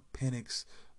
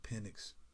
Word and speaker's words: Penix: I believe Penix, that's how you pronounce his name, Michael Penix.